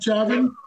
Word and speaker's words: Chauvin: Derek Chauvin.